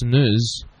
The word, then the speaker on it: snus: Apparently, it's pronounced snus.